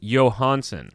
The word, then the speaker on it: Johansson: Here's what it says, the 21-22 NHL pronunciation guide. Marcus Johansson. – Johansson. – Johansson. – But then the announcers were saying Johansson.